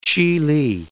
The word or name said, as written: Gulf of Chihli